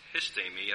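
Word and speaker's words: histemi (ἵστημι): Pardon me, it's histemi, I believe, is the correct pronunciation, to stand.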